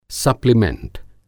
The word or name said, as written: सप्लीमैंट